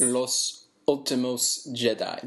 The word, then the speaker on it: Los Últimos Jedi: Los Últimos Jedi.